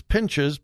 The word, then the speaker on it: Pinches: Now, you wouldn't think that a male had a distinct advantage over a female in pool, but Lynn Pinches, I have no idea how to really pronounce her name. It's Pinches, Pinches, P-I-N-C-H-E-S.